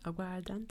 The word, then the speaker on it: aguardiente: And they have, we love to have a little beverage there called the aguardiente. Am I saying that right? Aguardiente? – Aguardiente, yeah.